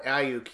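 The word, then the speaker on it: Aiyuk: Ayuk, yeah.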